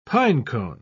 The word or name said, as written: pinecone